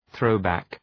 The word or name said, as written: throwback